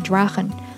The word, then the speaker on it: Drachen: 你看到在 Drachen 这个单词当中，ch 跟在元音的 a 后面，所以发 h 的音。 Drachen，Drachen。那这里边的字母 h 这个小舌音你可以唱不出来，但是千万不能学美国人民念成 Drachen，不太好听哈。